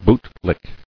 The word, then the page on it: Bootlick: boot·lick